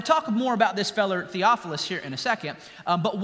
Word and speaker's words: Theophilus: Theophilus. Very good. You pronounced it well. I wanted to see who would trip over it. You did good.